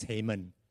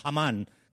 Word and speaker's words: Haman: Okay, I pronounce it after Benjamin Netanyahu. Before I watched that video, I thought it was Haman, like the Americans. So Haman comes in.